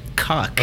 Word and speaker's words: Kauk: His last name is K-A-U-K. How would you say that? – Cock.